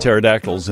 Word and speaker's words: pterodactyls: But with you running around with pterodactyls and all. – Yeah, I didn't have one as a pet, but I don't know. – Because the P is silent.